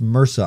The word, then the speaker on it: MRSA: It's pronounced MRSA, I'm being told. Thank you, breakdown in the chat. He's telling me that that it's MRSA.